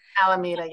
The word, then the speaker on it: Alameda: Alameda, yeah.